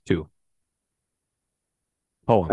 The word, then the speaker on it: poem: Does poem have one or two syllables? – Two. Poem. – I don't believe. Not the way I say it. – Do you just say poem? Like poem. Like, yeah. Poem. I believe poem has two syllables. That in my, I believe that's the way I read it. – I think that's a mispronunciation.